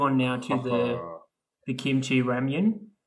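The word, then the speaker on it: Ramyun (라면): So ramyun, never say ramen to Korean because it'll remind them of the history with the Japanese.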